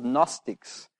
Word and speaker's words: Gnostics: They're called Gnostics. It starts with G, but that's a silent G. Ignore that bit. And the word is Gnostics.